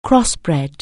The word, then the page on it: crossbred: anglais